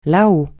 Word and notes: là-haut: francais

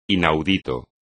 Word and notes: inaudito: espagnol

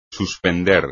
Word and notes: suspender: espagnol

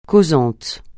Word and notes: causant: francais

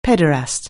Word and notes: pederast: anglais

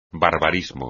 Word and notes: barbarismo: espagnol

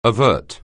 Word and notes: avert: anglais

